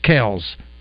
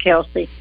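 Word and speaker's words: Kelce: Did you know that it was only in the last 24 hours that I had read the correct pronunciation of the brothers' names, the Swifty lover for sure, and that their names are pronounced Kells? – But they said they'd keep it Kelsey.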